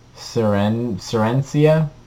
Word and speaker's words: Serencia: Seneca. – No, that's not what it's called. There's an R in there. – Derek Paris France? – Yeah. – Oh, I have no idea. – Serencia? – Just spell it. – S-E-R-E-N-C-I-A.